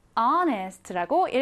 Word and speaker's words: honest: H가 소리 나지 않는 글자라서 마치 H가 없는 것처럼 honest라고 읽어주시면 됩니다.